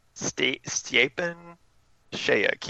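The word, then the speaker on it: Stjepan Šejić: It's Stepan Sayek. – Stepan Sayek? – Stepan Sayek. He has it spelled out phonetically on his Twitter page.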